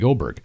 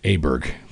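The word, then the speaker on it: Åberg: I had Ludvig Oberg, and we called him Aberg back then. But anyways, it changed. We got the proper pronunciation.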